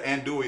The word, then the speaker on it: andouille: So that's like your andouille sausage that you knew. Am I saying that right? – Yeah, andouille is the perfect word for that.